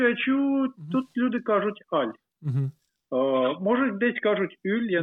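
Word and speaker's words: Hull: Французькою кажуть Аль. – Аль. – Аж не вимовляють. Ага. Аж Аль. Кажуть Аль. – Ага, окей. – Аж не вимовляють. Угу. Ну фактично, якщо вимовити місто на англійський манер, назву міста на англійський манер, ага, то це був би Хал.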